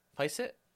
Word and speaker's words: Fiset: Feist? How would you pronounce this? F-I-S-E-T. Feist? – Trash.